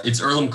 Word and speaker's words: Earlham: But yeah, it's Earlham College. Earlham spelled E-A-R-L-H-A-M, silent H.